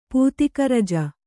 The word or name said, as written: ಪೂತಿಕರಜ